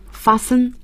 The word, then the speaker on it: fasten: 这个 t 是不发音的。 – 对， fasten unfasten， fasten your seat belts please。